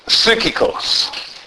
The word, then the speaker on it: psychikos: The word is psychikos. Now that's the correct pronunciation, but it's actually a Y. It's spelled P-S-Y-C-H-I-K-O-S. Psychikos.